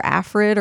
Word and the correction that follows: ARFID: It's ARFID.